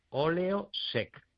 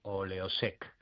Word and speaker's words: Oleosec: Oleosec. Acabado en C, Oleosec.